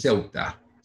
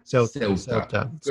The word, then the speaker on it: Ceuta: Help me again with the pronunciation. It is Ceuta. – Ceuta. Spelled C-E-U-T-A.